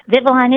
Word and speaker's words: Vivalon: Is Vivalon correct pronunciation? – Vivalon is the correct pronunciation.